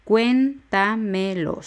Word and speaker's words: cuéntamelo: Cuéntamelo. Son cuatro sílabas. La última es lo, la penúltima me, la antepenúltima es ta y el acento va en la e.